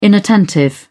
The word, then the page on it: inattentive: British English